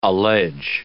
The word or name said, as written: allege